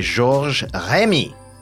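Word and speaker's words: Georges Remi: Created in 1929 by Georges Rémy, which is how it's pronounced properly, better known as Hergé, Tintin, or Tintin, became a worldwide star.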